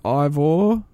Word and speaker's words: Eivor: So you'll play as Eivor, I'm assuming it's pronounced. It's spelled E-I-V-O-R. Would you say Eivor or would it be like Ivor? I think Brendan went with Ivor.